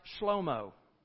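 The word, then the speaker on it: Shlomo (שלמה): Actually, in Hebrew, it's not pronounced Solomon. It's pronounced Shlomo.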